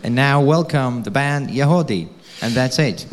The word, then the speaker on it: Yagody: Y-A-G-O-D-Y, but it's pronounced Yahode.